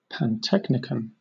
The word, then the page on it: pantechnicon: British English